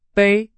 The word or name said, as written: 杯